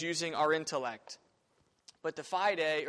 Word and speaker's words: fide: The word fideism derives from the Latin fide, pronounced, of course, fide, meaning faith.